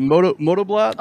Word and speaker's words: Motoblot: It is, I hope I say this right, was it Motoblot? – Oh, yeah, you got it.